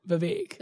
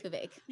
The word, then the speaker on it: Vivek: And I want to say Vivek, but it rhymes with mistake. Vivek. – Vivek.